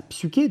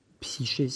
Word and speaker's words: psyché: Un visage de l'esprit, les, mots, euh, les, Grecs ont, ce mot, hein, euh, ils ont le mot de psyché, psyché si vous voulez, sauf que le Y en, grec se prononce U, enfin, c'est contradictoire, c'est comme ça.